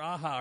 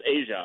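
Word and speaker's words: Aja: Yeah, Alexander Aha, or however you say his name. – I think it's Asia. – Is it Asia? Okay, Alexander Asia. – Yeah. Okay. – Like just like the band.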